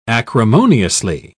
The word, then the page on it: acrimoniously: British English